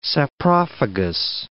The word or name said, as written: saprophagous